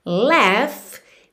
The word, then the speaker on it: laugh: Laugh se escreve Laugh, mas a pronúncia é laugh. E esse GH você vai fazer pronúncia de F mesmo, ok? Laugh. Laugh.